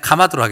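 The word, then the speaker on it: 카마드 (חמד): 이 히브리어 단어는 발음하기가 좀 그런데요. 카마드입니다. 예. 그냥 그냥 카마드로 하겠습니다.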